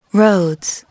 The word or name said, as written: roads